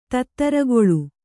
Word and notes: ತಿತ್ತಿರಿಗಾಳೆ: tittirigāḷe

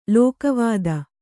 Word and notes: ಲೋಕವಾದ: Kannada

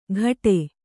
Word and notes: ಕಟೆ: kaṭe

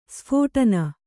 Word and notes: ಸ್ಫೋಟನ: sphōṭana

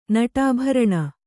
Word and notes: ನಟಾಭರಣ: Kannada

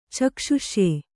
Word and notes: ಚಕ್ಷುಷ್ಯ: caksh'uṣya

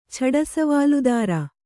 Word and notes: ಛಡಸವಾಲುದಾರ: Kannada

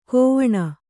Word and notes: ಕೋವಣ: kōvaṇa

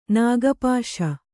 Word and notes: ನಾಗಪಾಶ: nāga pāśa

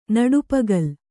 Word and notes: ನಡುಪಗಲ್: Kannada

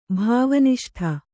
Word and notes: ಭಾವನಿಷ್ಠ: bhāva niṣṭa

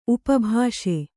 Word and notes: ಉಪಭಾಷೆ: upa bhāṣe

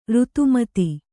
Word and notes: ಋತುಮತಿ: kannada

